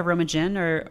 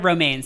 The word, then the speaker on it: Romijn: Rebecca Romijn or Romaine? No, Rebecca Romaine, sorry.